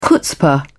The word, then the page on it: chutzpah: British English